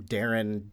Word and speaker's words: Daeron: I'm Darren, D-A-E-R-O-N.